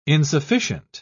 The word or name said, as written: insufficient